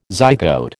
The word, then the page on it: zygote: American English